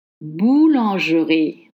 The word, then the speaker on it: boulangerie: M-E-D-I. The E is dropped. Samedi. Boulangerie. Maybe that's what you think you should say. No, it's boulangerie. Boulangerie. Let's study the pattern. G. E, R, I. The E is dropped. Boulangerie.